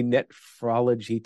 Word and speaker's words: nephrology: And what is a nephrology technology? I'm sorry for interrupting. I don't think I got that out right. Did I pronounce it right? – No, you said it right.